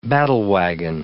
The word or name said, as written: battlewagon